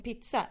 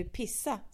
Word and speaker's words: pizza: Men hon säger, det är väl inte konstigt att man uttalar pizza som pizza, eller säger du pissa, Cecilia?